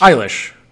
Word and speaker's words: Eilish: Eilish. Well, you know what? My my sister in law's name is Eilish, uh, and that's how it's pronounced.